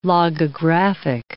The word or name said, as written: logographic